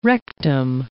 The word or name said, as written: rectum